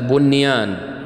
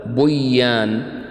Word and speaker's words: بنيان: وأيضا لفظ صنوان لا تقول صوان، وكذلك قنوان ما نقول قوان، وكذلك بنيان لا تقول بيان، لأن المعنى سيختلف لأجل اختلاف المعنى، ولأن العرب عندما تنطق بهذه الكلمات لا تدغم النون الساكنة في حرف الإدغام.